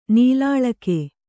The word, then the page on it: ನೂಲೆಳೆಗ: Kannada